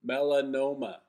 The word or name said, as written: Melanoma